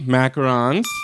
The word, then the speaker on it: macarons: Number three, macarons. – Yes. Great pronunciation on that.